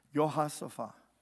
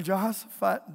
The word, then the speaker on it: Jehoshaphat: That's not the way you pronounce his name. There's no J's in Hebrew, but that's how we say it. Jehoshaphat.